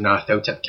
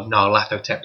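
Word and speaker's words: Nyarlathotep: for a bunch of guys, there's a Mask of Narthotep campaign, or Narlathotep, depending on how you want to pronounce it, that's about to kick off.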